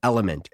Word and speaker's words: LMNT: You can fix this so simply with just one packet of Element. It's spelled L-M-N-T.